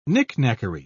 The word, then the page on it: knickknackery: British English